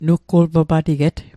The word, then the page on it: nutgulpapatiget: nutkul'papatiket